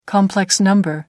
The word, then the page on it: complex number: American English